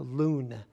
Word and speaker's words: Laun: L-A-U-N is pronounced Loon.